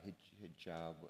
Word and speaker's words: hijab: Even for most Americans, we look at burqas or the hijab, how do you say it? – Hijab.